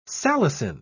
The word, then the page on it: salicin: British English